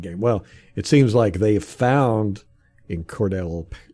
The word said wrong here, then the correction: Cordarrelle: how do you say his name? Cordarrelle Patterson, a hybrid running back wide receiver, and they using him.